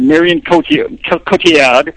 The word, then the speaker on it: Cotillard: There's a woman in there, Marion Cotillard.